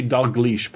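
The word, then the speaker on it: Dalgliesh: It'd be Dauglish, by the way. – Oh, I apologize. – There's a detective named Dauglish, I think. – Oh, okay. – That's why I know how to pronounce that.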